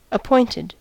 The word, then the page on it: appointed: American English